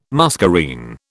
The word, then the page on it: muscarine: British English